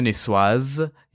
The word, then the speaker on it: niçoise: First of all, if I may, it's a tuna niçoise. You pronounce the S at the end.